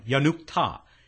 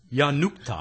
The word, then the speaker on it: Yanukta: It was Thomas Yanuka. Yanukta. They go to the trouble of giving us this phonetically, and then I ignore it. Thomas Yanukta.